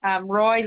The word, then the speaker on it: Roy: Roy Langdon. – Roy spelled as R-O-Y. – Roy, R-O-Y.